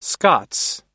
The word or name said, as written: scots